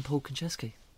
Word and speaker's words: Konchesky: His name is Paul Konchesky. Am I saying that right? Paul? – Yes, you are indeed. It is none other than Paul Konchesky.